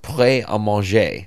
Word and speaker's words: Pret: Pret. – Yeah, this is a really fun. It's well, you got it. It's in French. It's prêt à manger, so ready to eat.